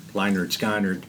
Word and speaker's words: Lynyrd Skynyrd: Lynyrd, Skynyrd.